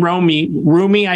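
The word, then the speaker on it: Roumie: Jonathan Rumi, I think he pronounces it, a very devout Catholic, plays Jesus.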